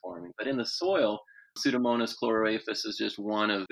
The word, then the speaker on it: Pseudomonas: Yeah, it's called Pseudomonas, and it's out of this genus and species designation that you learned about in seventh grade biology.